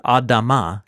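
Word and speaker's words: Adama (אדמה): And remember, the word ground is the word Adama, which rhymes with Adam, humanity.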